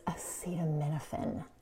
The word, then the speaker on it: acetaminophen: And one more thing, it's acetaminophen. That's how you say it.